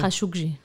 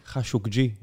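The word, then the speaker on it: ח'אשוקג'י: כן, אני חושב שמה שקרה עם אה, חשקוג'י, איך מבטאים את השם? חשוקג'י. – חשוקג'י.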